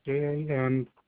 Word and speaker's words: Jamesha: Jamesha. – Jamesha. – J-A. – Jamesh. – E-S-H-A.